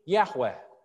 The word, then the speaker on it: Yahweh: But now, so the parallel is between the verb I am and the name Yahweh. Yahweh is how it would have been pronounced in Moses' day.